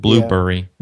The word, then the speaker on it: Blubrry: I think it's Blubrry. That's the reason why I said it so weird. Blueberry.